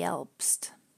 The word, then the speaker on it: Elbst: It's a water spirit that lives in a mountain lake in Switzerland, and the name ELBST sounds a little bit like Alb again. And remember, that was the first syllable of the German word for nightmare.